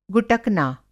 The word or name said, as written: गुटकना